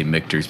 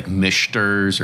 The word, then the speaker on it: Michter's: So that's kind of where I'm actually glad that you say Michter's, because I hear so many people butchered all the time, probably like your last name, right? And they say Michter's or Michner's or something like that, right? – So you're not the only one.